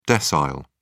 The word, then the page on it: decile: British English